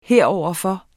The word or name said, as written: heroverfor